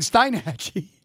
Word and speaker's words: Steinhatchee: Apparently, I've been mispronouncing it for like the last 17 years. But it's but anyway, I saw it last night on Weather Channel. It was Steinhatchee.